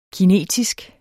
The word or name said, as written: kinetisk